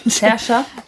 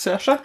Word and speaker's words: Saoirse: So, und Christine wird gespielt von, ich lass wieder dich aussprechen. – Sersha. – genau, immer so. Saoirse? Nein. Sersha Ronan, meine ich. Nach Song of the Sea ist es Sersha.